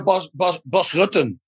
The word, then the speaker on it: Bas Rutten: Is this the same as Boz Rutten? That's official. That's how you pronounce it.